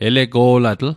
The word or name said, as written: elego'latl